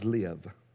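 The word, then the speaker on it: live (לב): And it's pronounced, it's spelled L-E-B, colon. But the B is not pronounced B. The B is pronounced with the V sound. The literal word, Hebrew word for heart is live.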